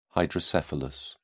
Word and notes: hydrocephalus: British English

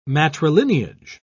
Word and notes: matrilineage: British English

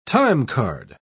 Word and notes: timecard: British English

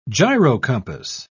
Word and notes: gyrocompass: British English

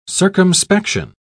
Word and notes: circumspection: British English

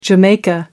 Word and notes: jamaica: American English

